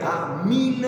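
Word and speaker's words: amin: Una raccomandazione nell'allegro finale, la pronuncia è a min e alliluia, con la I e non con la E.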